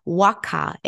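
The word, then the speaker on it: Waaqa: So they tell a story about their god, and I'm going to spell it in case I am mispronouncing it, but I believe it is the Waka. It's W-A-A-Q-A.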